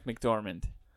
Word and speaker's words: McDormand: Well, that sounded like McDormand. – There you go. – Okay. – You said it right that time.